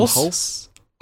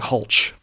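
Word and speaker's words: Hulce: How do you pronounce his last name? It's like Tom Hulse. Okay, Hulse. Okay. – I have Hulse, yeah. – All right. I was getting Hulch for a bit.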